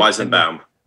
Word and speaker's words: Weizenbaum: And these two people are, you have Joseph, and let's see if I can pronounce his last name, Weizelbaum, right? – Weizenbaum.